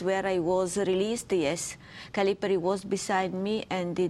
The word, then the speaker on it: Calipari: The Italian intelligence agency, Calipari, is that how you pronounce it, Calipari? – Yes.